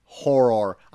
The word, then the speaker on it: horror: Horror. I've, in my recent years, have really gone and hit the double R hard.